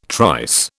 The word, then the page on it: trice: British English